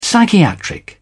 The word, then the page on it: psychiatric: British English